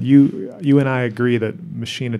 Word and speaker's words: Machina: Okay, well, the album after that is Machina. – And I think you're right, that's the correct pronunciation of that word, but I've always heard Billy refer to that album as Machina.